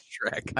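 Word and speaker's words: Schreck: Is it Shrek? Actually, is that how you pronounce it? Fuck. – It is Shrek.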